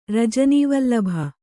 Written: ರಜನೀವಲ್ಲಭ

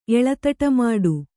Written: ಎಳತಟಮಾಡು

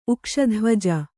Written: ಉಕ್ಷಧ್ವಜ

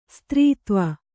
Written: ಸ್ತ್ರೀತ್ವ

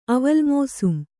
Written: ಅವಲ್‍ಮೋಸುಂ

